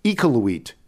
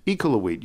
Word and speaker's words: Iqaluit: Sarah Beth from Toronto, but currently, and it's pronounced Iqaluit. Iqaluit. You're probably saying it wrong, but it's okay.